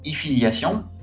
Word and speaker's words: Efiliation: Ensuite, on a Efiliation. Là, je prononce toujours à l'anglaise, mais c'est E, ensuite Filiation, comme si tu écrivais Affiliation.